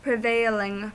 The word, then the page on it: prevailing: American English